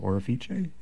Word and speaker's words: Orefice: And he did play Chopin, and the opera, which is called Chopin, is by Giacomo Orefice. Is that my mispronouncing it? – Sure. Sounds good to me.